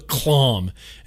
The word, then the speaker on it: Klohm: Uh, it's called the Clom. Great. Cool name, bro. It's called the Asmodus Clom. K L O H M. Any other way to pronounce that? No. It's called the Clom.